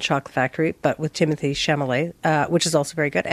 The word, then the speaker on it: Chalamet: Well, it's Chalamet.